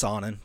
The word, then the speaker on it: Sonnen: It's Sonnen. Chael Sonnen. – Oh. Chael Sonnen. – That is from the man himself.